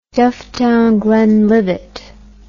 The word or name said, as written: Dufftown-Glenlivet